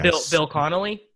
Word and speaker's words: Bill Connolly: You know, I think Bill Connell. I always get his name wrong. – The SB Nation guy. – SB Nation guy. – Bill Connolly.